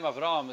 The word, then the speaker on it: Avraham: By the way, when the name Avraham is said in Lashon Kodesh, it's pronounced Avraham. But many times when it's pronounced in English, it's Avram. Avram Avinu, whatever. It's sort of, the H gets lost.